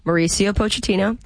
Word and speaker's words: Mauricio Pochettino: The risks that were taken by, let's say his name correctly, the head coach, Mauricio Pochettino.